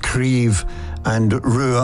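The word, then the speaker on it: Craobh Rua: Now, Croí-Brua, it means red branch in Irish, but it's spelled C-R-A-O-B-H, and it's pronounced Creve and Rua, R-U-A.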